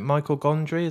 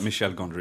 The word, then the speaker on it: Michel Gondry: Is it Michael Gondry? Is that how you pronounce his surname? – I think it's Michelle, isn't it? – Oh, well. – It's a blood, it's a fella, it but he's French, so it's like Michel Gondry.